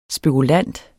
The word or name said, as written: spekulant